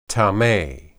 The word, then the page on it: tame: hebrew